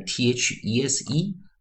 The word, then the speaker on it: these: 好，所以我们看 t h e s e，我们念 these，these，these。所以 t h，我们如果念成浊音啊，或有声或浊音，我们念 z，z，z。